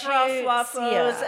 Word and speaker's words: stroopwafels: Stroopwafels.